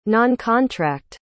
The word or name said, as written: noncontract